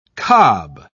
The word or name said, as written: cob